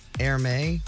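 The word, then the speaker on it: Hermès: I believe it's pronounced Hermé Doghouse. Is that right? – Hermé. – Hermé? Okay. $19,000. – Hermes. – Hermes is what it looks like.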